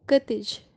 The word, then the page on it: katij: Kashmiri